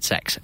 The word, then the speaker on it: CeX: It is the best, uh, and I believe they pronounce it sex in- inexplicably.